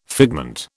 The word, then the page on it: figment: British English